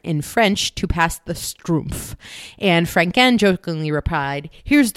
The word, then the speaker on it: schtroumpf: Strumpf. – Okay. – It is pronounced like the German word Strumpf, meaning sock.